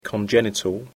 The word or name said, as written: congenital